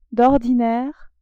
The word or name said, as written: d'ordinaire